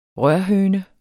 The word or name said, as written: rørhøne